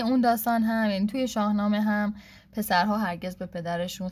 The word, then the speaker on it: شاهنامه: و بعد اونجا یه مثال زد از شاهنامه که برای من خیلی جالب بود، البته که تلفظش خیلی افتضاح بود از کلمه شاهنامه، و گفتش که شنهمه یه همچین چیزی، یعنی اصلا اشتباه گفت کلا، چه آخرش هم گفته شنهمه،